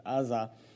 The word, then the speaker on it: Uzzah: Uzzah, or Uzzah, however you like to pronounce it. I like to pronounce it Uzzah.